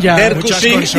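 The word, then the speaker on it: Drkušić: Yo lo diría así, no sé cómo se dice, pero yo diría Drusik. – Derkusik, Derkusik, me han dicho a mí que.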